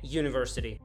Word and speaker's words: university: А в слове university буква U раскладывается на два звука – Й и У. Как мы знаем, звук Й согласный, поэтому E university.